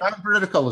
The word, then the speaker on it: non-veridical: And often non-veridical, or I don't know how you say it, like strategies like non-veridical is right. Non-veridical is correct.